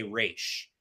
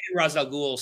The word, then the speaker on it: Ra's al Ghul: Ra's al Ghul says it's pronounced Ra's, but he says he's based it on Arabic, so thus he's actually wrong about his own thing.